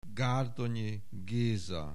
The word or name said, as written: Gárdonyi Géza